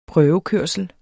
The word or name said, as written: prøvekørsel